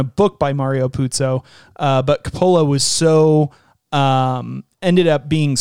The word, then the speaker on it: Coppola: So this was directed by Francis Ford Coppola, and I'm going to mispronounce Coppola like five times, but it is, I'm pretty sure, Coppola. Supposed to be pronounced Coppola.